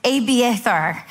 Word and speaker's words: Abiathar: It's Abiathar, son of Ahimelech, bring me the ephod.